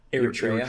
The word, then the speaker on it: Eritrea: Eritrea.